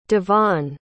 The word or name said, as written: Devaun